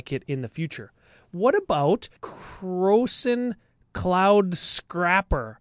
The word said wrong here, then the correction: Scraper: Did I say that right? – I think it's Scraper.